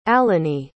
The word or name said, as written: alinae